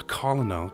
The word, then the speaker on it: colonel: How to say colonel? Because it just looks weird, right? I know I'm not saying it right. It's colonel, right? But it looks so weird when you see it.